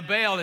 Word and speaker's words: Baal: Now, Baal is spelled B-A-A-L. Religious scholars would pronounce that Baal. Southern preachers call it Baal.